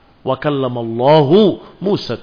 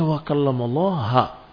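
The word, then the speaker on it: Wa kallamallahu Musa (وَكَلَّمَ اللَّهُ مُوسَى): Allah mengajak bicara Musa dengan dhammah. Pada lafdul jalalah. Wa kallamallahu Musa taklima. Tetapi bacaan tadi didengar oleh seseorang. Kok bacanya wa kallamallaha. Mengajak bicara Allah Musa. Berarti Musa yang berbicara. Karena kalau wa kallamallaha dengan fathah.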